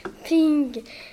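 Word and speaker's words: Ping (苹): dont le premier caractère Ping se prononce comme le mot chinois qui veut dire paix.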